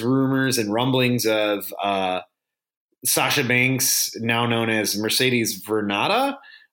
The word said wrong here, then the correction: Varnado: right? Did I say that right? – Varnado.